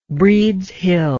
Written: breeds hill

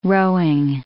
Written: rowing

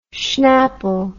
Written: schnapple

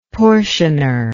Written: portioner

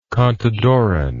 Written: contadoran

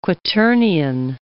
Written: quaternion

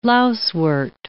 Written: lousewort

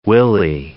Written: willy

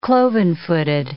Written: cloven-footed

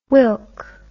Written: whilk

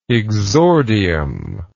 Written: exordially

